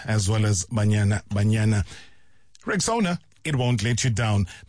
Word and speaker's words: Rexona: It's pronounced Rexona. Simple, strong, and easy to remember.